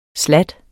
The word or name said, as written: slatten